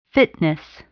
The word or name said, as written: fitness